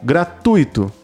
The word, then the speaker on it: gratuito: Segundo as regras de acentuação da língua portuguesa, não deverá ser acentuada graficamente e deverá ser pronunciada corretamente com a tonicidade na sílaba tui, como gratuito.